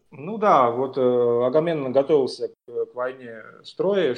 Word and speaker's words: Агамемнон: Если вдруг кто не в курсе, там рассказывается о царе Агамемноне, не знаю, где ударение ставить. – Агамемнон. – Агамемнон, да.